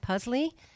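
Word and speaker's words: pusley: And this is pronounced, I think, it's P-U-S-L-E-Y, Puzzley. – Yes.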